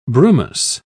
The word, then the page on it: brumous: British English